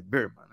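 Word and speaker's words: Bearman: Eu falei Berman, mas é Birman né,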